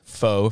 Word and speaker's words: pho: Yeah, pho you, dude. – I say pho because it looks P-H-O.